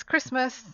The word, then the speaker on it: Christmas: So it's Christmas, even though there's a T there.